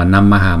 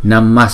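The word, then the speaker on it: namaḥ: Chữ này là viết là Namaha, nhưng mà chúng ta không đọc là Namaha, mà chúng đọc, chúng ta sẽ chuyển cái Visarga này thành chữ S, Namas Sanghaya Mahate.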